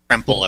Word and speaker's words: Kremple: Kremple, I believe. – The club does pronunciation. – Parking. What is it? – Kremple. K-R-E-M-P-L-E, Kremple.